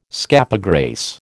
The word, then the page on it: scapegrace: American English